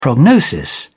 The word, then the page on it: prognosis: British English